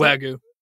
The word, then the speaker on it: Wagyu: Wagyu. – Wagyu. – I'm not a good pronunciator, but Wagyu.